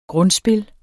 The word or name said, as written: grundspil